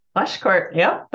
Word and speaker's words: Pleschourt: I hope I pronounced this name correctly. Tracy Pleschourt? – Pleschourt, yep, that's right.